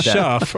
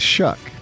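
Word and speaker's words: Shough: It looks like Shuff or... – I know, and I screwed up. – Shuck. – It's Shuck.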